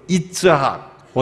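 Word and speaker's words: 이츠학 (יצחק): 이 이삭이란 단어는 히브로 이츠학 원 발음은 이츠학 그 의성어예요.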